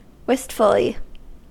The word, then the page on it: wistfully: American English